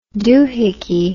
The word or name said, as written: dohickey